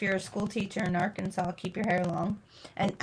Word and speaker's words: Arkansas: Arkansas must be pronounced Arkansas.